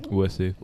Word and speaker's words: Wisse: Wisse.